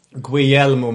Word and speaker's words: Guglielmo: Guglielmo Marconi.